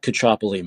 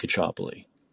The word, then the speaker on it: Caccioppoli: I think I'm the only Mike Cacciopoli on Twitter. Yeah, it's at Cacciopoli Mike. At Cacciopoli Mike. And to spell it, do I need to spell my name? It's a CACCIOPP, as in Peter, OLI, Mike.